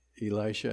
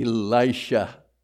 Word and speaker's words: Elisha: But if you look at it phonetically, if you apply your phonics to it, it should be a short I, Elisha. So if I lapse into Elisha, I mean Elisha.